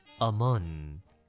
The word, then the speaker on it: among: 今天我们看这两个字是 among 以及 between 来，第一个字的发音有点难念，来我们念一下，念成 among OK，它这个 O 念的是 A 的这个音，所以不能念成 among，不是这样，是 among 好，什么是 among 跟 between 呢？